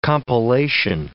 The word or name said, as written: compilation